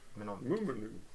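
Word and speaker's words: Mnemonic: Eh, och det här var en låt som lite svårt att uttala. Eh, men... – Menomic. – Mnemonik. – Mnemonik.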